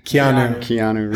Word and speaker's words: Keanu: Keanu Reeves.